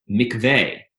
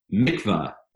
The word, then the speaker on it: mikveh: The word for the gathering of the waters, used twice, is the word mikveh, sometimes pronounced mikveh in American Jewish parlance.